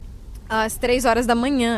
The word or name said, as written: às três horas da manhã